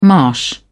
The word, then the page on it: marsh: British English